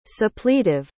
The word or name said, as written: suppletive